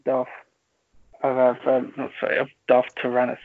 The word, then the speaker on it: Darth Tyrannus: But even in modern times, we've seen Asajj Ventress be the apprentice of Darth Tyrannus. Is that how you pronounce it? – Yeah.